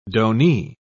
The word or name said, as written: donee